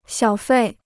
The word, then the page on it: 小费: xiǎo fèi